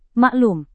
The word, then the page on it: maklum: Indonesian